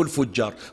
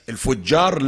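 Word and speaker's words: الفِجار: بس تنطق خطأ عندنا احنا في اللهجة الدارجة تنطق خطأ يسمونها حرب الفجار حتى في المدارس يعلمون بعض الأساتذة مو الكل بعض الأساتذة يعلمون الأولاد يقولون حرب الفجار هو مو الفجار لا الفجار بكسر الفاء بفتح الجيم الفجار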